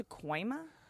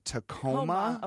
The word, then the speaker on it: Tacoma: Is it not pronounced Tacoima? Or is it Tacoma? – I think there might be a Tacoima, but Tacoma.